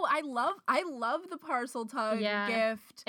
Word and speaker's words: Parseltongue: I want to talk about Parseltongue, or is that how you say it? – Parseltongue, yeah.